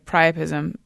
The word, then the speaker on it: priapism: And by the way, priapism, which I'm possibly pronouncing wrong. – No, that's right.